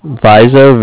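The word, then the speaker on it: Vizzo: Vizo, V's and Victor, I-Z-Z-O.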